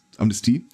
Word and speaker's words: Amnestie: Amnesie aussprechen, Amnest Amnestie, und äh, ihnen eine Medaille umhängen.